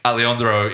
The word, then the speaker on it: Alejandro: Yeah, we do this every week, Alejandro Iñárritu. We film this in one take, no edits. Not that impressive. Go on, Frank. – Beautiful pronunciation of Alejandro's name. – Thank you.